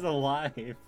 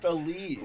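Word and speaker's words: Aleve: That says alive. – No, it says it's called a leave. – That is the word alive, you fool. – It's pronounced a leave. – Why would it be pronounced that? – Type that in. Watch a YouTube advertisement for a leave. They don't pronounce it alive.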